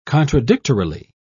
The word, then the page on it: contradictorily: British English